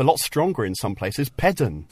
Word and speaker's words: Pedn: We had this extra sound built in. It only happened when the syllable was stressed. So you'll get Penzance, but you'll get Pedn and Les.